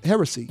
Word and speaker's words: Heresy: That was Heresy, or Heresy, yeah, that's how I pronounce it. H-E-R-E-S-Y.